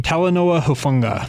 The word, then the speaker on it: Talanoa Hufanga: Talanoa Hufanga.